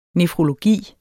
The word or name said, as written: nefrologi